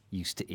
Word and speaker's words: used to: Now, pronunciation-wise, we say used to with a short y sound. Used to. Not a long u, like in can I use your phone, please?